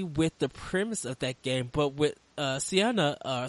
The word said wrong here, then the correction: Senua: sweet, I can never say her name right. – Oh, yeah, it's kind of a weird one. It's Senua.